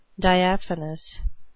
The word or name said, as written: diaphanous